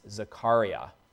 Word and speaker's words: Zechariah: We're looking at selected verses from Zechariah, or as a rabbi corrected me, it's often pronounced Zechariah.